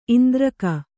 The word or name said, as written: ಇಂದ್ರಕ